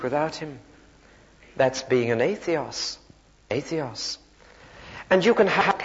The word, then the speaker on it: atheos: It's spelt A-T-H A-T-H-E-O-S.